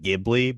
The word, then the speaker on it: Ghibli: So it's Hot Desert Wind, and it's an Italian, in the Italian way, it would pronounce more like Ghibli, but they put the J on it.